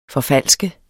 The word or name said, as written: forfalske